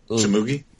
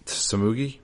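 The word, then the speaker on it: Tsumugi: because Shuichi and Tsukumi, hey hey pronounce her name? – Samugi. – Samugi. – Samugi. Yeah.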